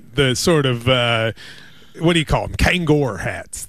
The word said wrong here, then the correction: Kangol: Yeah, Kangol hats. You said Kangor.